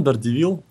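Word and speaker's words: Daredevil: Дардивил. Прекрасное. Никогда в жизни не слышал еще такого варианта. Это гар... называется гарвардский акцент. Гарвардский акцент. Дардивил. Дардивил. Как кардиган Дардивил.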